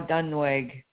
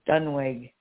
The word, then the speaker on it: Duenweg: Shelby Sue Ong, missing from, I don't know how to say this word, D-U-E-N-W-E-G. Missouri. – Dunwig? – Yeah. No, Dunwig. There's no A in between. Dunwig. – That's what Missouri. – I said, Dunwig. – Oh, it sounded like you said Dunwig.